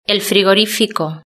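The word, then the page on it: el frigorifico: Spanish (Spain)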